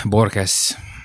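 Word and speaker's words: Borges: A helyes kiejtése Borkesz, és úgy néha reménykedem abban, hogy ezek úgy itt-ott egy-egy megfogalmazásomban vagy, vagy bekezdésemben visszaköszönnek.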